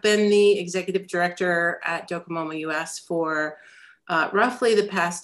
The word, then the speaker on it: Docomomo: Today on the Practical Preservation Podcast, I have with me Docomomo US. Did I say that right? – Docomomo.